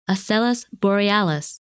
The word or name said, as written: Asellus Borealis